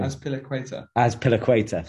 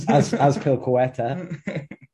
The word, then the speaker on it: Azpilicueta: The only thing I would say is if Azpilicueta, how do you say his name? – Azpilicueta, thank you. Very well pronounced. – Yeah, as a Leo Capel. This is brilliant. Bloody brilliant. – Azpilicueta.